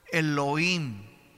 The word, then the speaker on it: Elohim: Esta palabra Dios viene de una palabra hebrea que se pronuncia en hebreo, Elohim, Elohim.